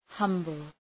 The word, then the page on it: humble: ‘hʌmbəl